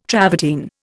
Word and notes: travertine: Indian

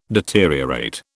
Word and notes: deteriorate: British English

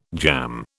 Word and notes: jamb: British English

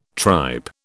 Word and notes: tripe: British English

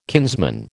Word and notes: kinsman: American English

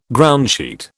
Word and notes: groundsheet: British English

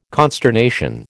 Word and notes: consternation: American English

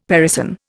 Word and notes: parison: Indian

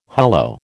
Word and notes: hullo: American English

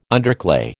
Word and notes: underclay: American English

